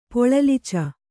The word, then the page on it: ಪೊಳಲಿಚ: Kannada